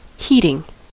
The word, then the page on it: heating: American English